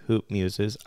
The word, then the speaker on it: Hoop Muses: So you're out with your new book this week, Hoop Muses. I made sure to say it right this time when I screwed it up the first time.